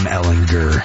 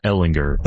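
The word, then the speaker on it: Ehlinger: It is Sam Ellinger. Hard G. Not a Sam Ellinger.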